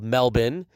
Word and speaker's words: Melbourne: I was in Melbourne, but as the locals say, it's called Melbourne.